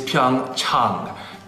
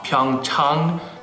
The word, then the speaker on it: Pyeongchang: The correct pronunciation is Pyeongchang. Chang, ah, ah, like when you go see your doctor, ah. Pyeongchang. Pyeongchang.